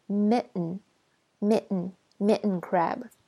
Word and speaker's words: mitten: 那这边再设计一下发音，像 mitten 这个东西是吧？理论上发音是这样的，但是在美式英语呢，这个 T 会被 hold 住，所以我们会说 mitten crab。